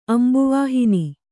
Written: ಅಂಬುವಾಹಿನಿ

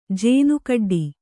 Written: ಜೇನುಕಡ್ಡಿ

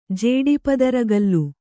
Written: ಜೇಡಿಪದರಗಲ್ಲು

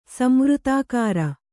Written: ಸಂವೃತಾಕಾರ

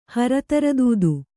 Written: ಹರತರದೂದು

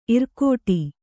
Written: ಇರ್ಕಡೆ

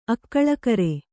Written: ಅಕ್ಕಳಕರೆ

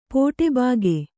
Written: ಪೋಟೆಬಾಗೆ

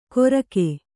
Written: ಗೊರಕೆ

